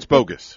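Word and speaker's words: Bogguss: Did I pronounce her name right? – It's Bogus. – It is Bogus? – Yes, ma'am.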